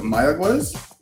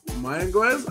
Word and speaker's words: Mayagüez: Is it Mayagüez? – Mayagüez? I like that. That sounded good.